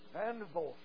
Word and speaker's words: Vanderbilt: How do you say it? Bon what? Vanderbilt, yeah.